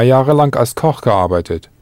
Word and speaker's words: gearbeitet: Ja, oder dort habe ich 2 Jahre lang gearbeitet. That's G-E. A-R-B-E-I-T, E-T, gearbeitet. Note that, for ease of pronunciation, you had to add an E-T to Arbeit, not just the normal T.